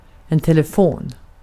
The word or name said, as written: telefon